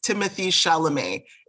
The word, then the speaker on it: Timothée Chalamet: In terms of his name, I believe it's Timothée Chalamet is how they pronounce it.